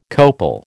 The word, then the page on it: copal: American English